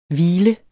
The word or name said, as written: hvil